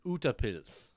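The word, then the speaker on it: utepils: So the word is, I think the way you pronounce it is utapils. Utapils.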